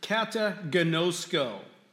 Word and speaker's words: kataginosko: One of them is this Greek term, kataginosko. By the way, those are English letters. That's not the way it would look in a Greek Bible. Kataginosko is how you would pronounce this particular term, though.